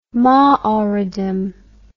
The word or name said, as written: Maoridom